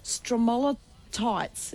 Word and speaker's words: stromatolites: But another major attraction are the ancient stromatolites. Is that how you say it? – Yes.